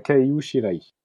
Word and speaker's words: Kaiu Shirai: de nous dire comment on prononce bien les, les, les noms de ces deux auteurs. – non, pas trop. Je lis mieux que, que je parle, mais euh, normalement c'est Posca Demiso et euh, Kaiu Shirai.